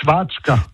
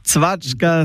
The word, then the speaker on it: Zwatschka: Bei uns sind es Zwatschka. – Zwatschka, es ist Zwatschka, war ja fast. – Zwatschka, voilà. – Wie du, habe ich es richtig gesagt, ja? – Ja.